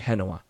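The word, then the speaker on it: Génova: It's spelled Genova, but it's pronounced in Spanish, Genoa.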